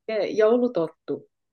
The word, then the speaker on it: Joulutorttu: Joulutorttu.